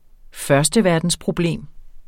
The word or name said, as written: førsteverdensproblem